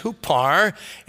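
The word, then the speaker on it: hupar (ὑπέρ): The second word for is pronounced hupar, and it means on behalf of or for the sake of.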